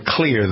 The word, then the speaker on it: Xlear: In this case, Clear is spelled X-L-E-A-R. Clear is a natural sinus care system that hydrates while it works against the contaminants. It is, the reason it's spelled X-L-E-R, E-A-R, is because the main ingredient is xylitol, which begins with an X.